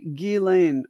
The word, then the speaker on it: Ghislaine: And that I think she's the one that told me it's actually Ghislaine Maxwell. That's how you pronounce it. It's with no S. You don't say the S, apparently.